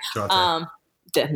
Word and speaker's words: Jonte: and Jonta, like, or Jonte, I hope I'm saying his name right. – Jonte.